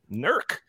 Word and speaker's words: NERC: So the author of this piece talks about a recently released assessment forecast from the North American Electric Reliability Corporation, or NERC. Is that how you pronounce it, Isaac? – That's correct. – NERC.